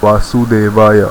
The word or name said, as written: Vasudevaya